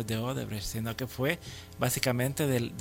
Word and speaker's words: Odebrecht: Sí, y en estos casos uno no tiene que quedarse en la persona jurídica de que van a haber contratos necesariamente con Odebrecht. ¿Cómo se dice? – Odebrecht.